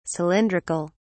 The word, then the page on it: cylindric(al): English; British English